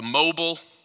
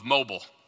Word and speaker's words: Mobile: All week long, she pronounced it Mobile, to the point that by the end of the week, Jen and I, my wife and I, started saying Mobile. It's not Mobile, it's Mobile. But what we were consistently hearing led us to act in a way that was not accurate.